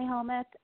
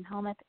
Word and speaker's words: Helmuth: But my name is Molly Helmuth, M-O-L-L-Y, last name Helmuth, H-E-L-M-U-T-H, and I like to spell it just so that people know.